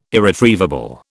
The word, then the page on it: irretrievable: British English